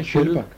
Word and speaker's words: Kyll: Säger du den en eller inte? – Nej, jag säger inte den en, jag säger bara Kyll.